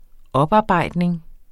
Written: oparbejdning

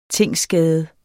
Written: tingsskade

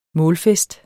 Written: målfest

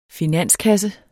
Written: finanskasse

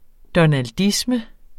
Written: donaldisme

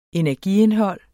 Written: energiindhold

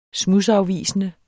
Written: smudsafvisende

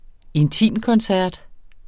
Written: intimkoncert

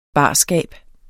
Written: barskab

